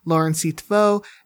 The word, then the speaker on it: Lauren C. Teffeau: Lauren C. Teffeau.